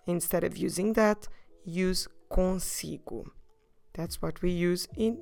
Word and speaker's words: consigo: consigo.